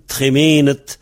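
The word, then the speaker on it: tremened: Quand c'est un nom, on met ED. Un tremenet. Mais ça se prononce pareil. Parce que le T et le D se prononcent pareil en final.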